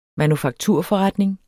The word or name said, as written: manufakturforretning